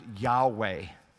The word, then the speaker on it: Yahweh: Probably the proper Hebrew vowel points would make the word would be pronounced Yahweh. And Yahweh is the name of God. I wish it didn't say capital L-O-R-D in our Bibles.